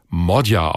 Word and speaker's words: Magyar: Der schreibt sich zwar Péter Magyar, also mit G und Y im Nachnamen, spricht sich aber Modja aus.